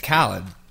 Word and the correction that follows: Khaled: right? – Khalid. – Is it? – Mm-hmm.